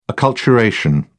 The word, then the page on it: acculturation: British English